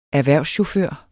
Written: erhvervschauffør

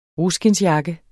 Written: ruskindsjakke

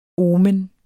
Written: omen

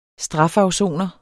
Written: STRAFAFSONERE

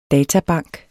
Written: databank

